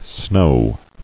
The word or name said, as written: Snowing